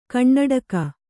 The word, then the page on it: ಕಣ್ಣಡಿಗ: Kannada